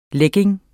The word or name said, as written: leggings